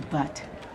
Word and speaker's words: Butte: Her teal blue fingernail trails past several cities and lands on Butte. At a ticket counter. – I'd like a ticket to Butte. – I believe it's pronounced Butte, Montana.